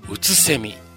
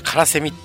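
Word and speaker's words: 空蝉: 第 2の章はね、うつせみというタイトルなんですよね。あの、空にね、セミと書いてね、うつせみなんですよね。間違ってね、僕ね、カラセミって読んじゃいそうになっちゃって、あれちょっと待てよと思ってね、さっき確認してよかったです。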